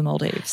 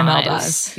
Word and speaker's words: Maldives: Is it the Maldives? – Well, I asked when I was there. I was like, how do I pronounce it? And they told me it was the Maldives.